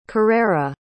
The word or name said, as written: carrera